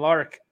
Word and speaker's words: Larck: Of course, I'm Dylan Lark at Blood Tank on Twitter/X. Who you type in, it redirects. Also, have me on Instagram as well at Dylan Lark at DYLAN, only correct way to spell it again, LARCK.